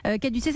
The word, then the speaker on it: Caducé: Caducé, ça s'écrit Caduc, en fait, ça s'écrit C-A-D-U-C.